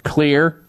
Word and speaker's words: Xlear: And so you need to keep your nose clean with a therapeutic nasal spray called Clear, X-L-E-A-R. That's how you spell it, X-L-E-A-R, nasal spray.